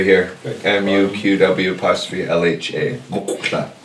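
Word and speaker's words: muqw'lha: It's QW apostrophe. Mukla. See, everyone, we grew up saying it wrong.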